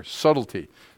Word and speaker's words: subtlety: So to me, it's highlighted there, subtlety, okay? The B is silent.